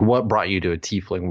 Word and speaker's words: tiefling: Oh, tiefling.